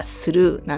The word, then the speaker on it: slough: SLOUGH って書いて発音がスルーなんですよ。